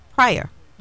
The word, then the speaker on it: Prieur: So we just want to tell our listeners who we're speaking with Dr. Josh Prior. You know, your name is spelled P-R-I-E-U-R, but it's Prior, mm-hmm.